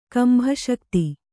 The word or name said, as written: ಕಂಭಶಕ್ತಿ